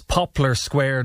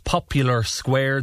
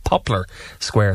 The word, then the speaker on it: Poplar Square: Could you please inform listeners that it's Poplar Square, not Popular Square? The name came from the row of poplar trees there long ago, says Elaine. That's right, Elaine. Yeah, it's spelled P-O-P-L-A-R. So it's not a square that is very popular, but it's Poplar Square.